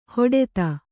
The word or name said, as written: ಹಟಾತ್ತು